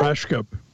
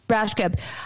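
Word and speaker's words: Rajskub: Mary Lynn Raska? – Rashkup. – Rashkup.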